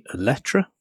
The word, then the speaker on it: Eletre: Yes, and if you were not under a rock, you know that Lotus has revealed their new SUV, the Eletre. Is that how they're pronouncing it? – I believe so, yes.